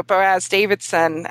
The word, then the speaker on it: Boaz Davidson: Boaz Davidson, and I hope I'm saying his name right.